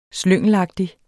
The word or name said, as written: slyngelagtige